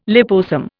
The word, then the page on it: liposome: Indian